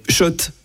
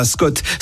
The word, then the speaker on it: Schott: Oui, Schott. Et pas Scott, ça fait des années qu'on prononce mal la marque.